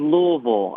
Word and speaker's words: Louisville: I learned that whenever we first moved up here, I said Louisville and I was corrected. I was like, okay, so we're doing the silent I thing.